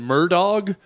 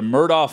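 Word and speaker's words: Murdaugh: I have a hard time saying Murdaw, the way they pronounce it. – Murdaw, like you said. It should be Murdaw, but the family mispronounces their own name. – Yeah. – Alex, it's A-L-E-X, and he tells everyone his name is Alec.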